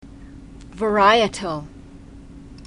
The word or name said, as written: varietal